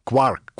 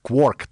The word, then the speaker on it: quark: Ну, все знают, что кварк взят из Джеймса Джойса, потому что гуси летят и говорят «three quark for Mr. Mark», кварк, кворк, тут разные люди по-разному произносят.